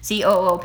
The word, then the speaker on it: Coop: The grocery store was called Coop. I'm guessing this is how you say it. Coop. COOP.